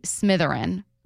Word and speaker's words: Smitherin: Long Beach State AD Bobby Smitherin, rhymes with Slytherin,